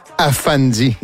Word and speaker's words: Afandi: Afandi est le titre de l'album, A-F-A-N-D-I, comme ça se prononce.